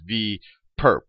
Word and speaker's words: V perp: So we write this little orthogonal notation as a superscript on V, and you can pronounce this as V perp, not for perpetrator, but for perpendicular.